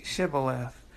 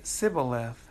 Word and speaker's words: Shibboleth: And if they said no, they're not an Ephraimite, verse 6, then they would say to him, then say Shibboleth. And he would say Sibboleth, for he could not pronounce it right.